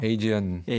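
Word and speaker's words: Aegean: 그래서 자켓에도 그 그리스 바다가 이렇게 나와 있는 자켓인데, 근데 사실 이것도 영어로 발음하면 에게가 아니잖아요. – 에이지언, 에지언이 되잖아요.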